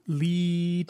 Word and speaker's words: Leigh: Lee? I think that's how you pronounce L-E-I-G-H. – Last time I said Lee and you said Lay.